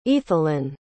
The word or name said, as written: eathelin